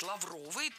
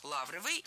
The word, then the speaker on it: лавровый: Но если кому-то кажется такой вариант странным, и если вы привыкли говорить лавровый, то переучиваться не надо, ведь норма ударения в этом слове двойная. Лавровый и лавровый.